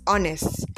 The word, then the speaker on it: honest: Like when you say, spell the word H-O-N-E-S-T, as in honest, some people pronounce it as honest. Honest or honor or hour. It is not pronounced that. The H is silent. So it is ah, honor, honest. Okay, so let's be mindful about that. Then again, the word spelled I-S-S-U-E, which people pronounce as issue, is not issue.